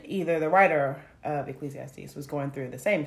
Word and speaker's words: Ecclesiastes: I mean, I think what really, when you talk about the Ecclesiastes, am I saying that right? Mm-hmm.